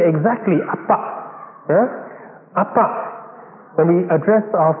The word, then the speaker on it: Abba: Even with the right intonation, and exactly in the Aramaic, Appa, with the emphasis on the second syllable.